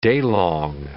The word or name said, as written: daylong